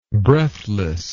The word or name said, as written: breathlessness